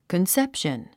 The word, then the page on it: conception: kənsépʃən; コンセプション